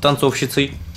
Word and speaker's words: танцовщицей: Сначала она начала работать танцовщицей, прошу прощения, в кабаре. – Танцовщицей было правильное ударение.